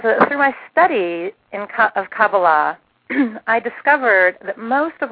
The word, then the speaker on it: Kabbalah: And then in my 30s, I happened to learn a little something about Jewish mysticism, or Kabbalah, which is correctly pronounced Kabbalah, although nobody ever pronounces it that way. Even most Jews don't pronounce it that way, but that's the correct pronunciation. It is Kabbalah.